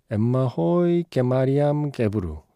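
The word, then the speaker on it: Emahoy Tsege Mariam Gebru: 엠마 호이 게마리암 게브루. 네, 이게 그나마 좀 근접한 발음으로 제가 흉내를 낸 건데 에티오피아의 뮤지션이고 수녀님이세요.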